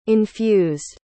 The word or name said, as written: infused